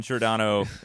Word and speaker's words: Giordano: On Giordano and Klingberg. Am I saying this right? Am I doing this right? – Yes, you are.